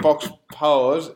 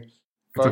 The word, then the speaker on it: faux pas: So, I guess saying fox paws is a faux pas on pronouncing faux pas.